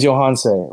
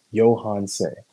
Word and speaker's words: Johanse: My name's Johanse. Rhymes with Beyonce, starts with a Y. Johanse.